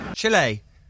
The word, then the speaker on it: Chile: I always thought it was Chile. – No. – I like the... – Listen again. – He's a journalist, very well-known journalist here in Chile. – Chile. That's how you say it.